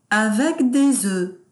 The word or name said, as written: avec des oeufs